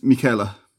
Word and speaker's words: Michela: Our next email comes from a Michelle Michele. This person clearly is Italian. – It's Michela. – Michela.